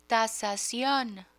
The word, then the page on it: tasacion: Spanish